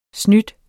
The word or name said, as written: snyder